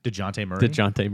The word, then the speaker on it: Dejounte: DeJounte Murray.